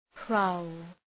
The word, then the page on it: prowl: praʋl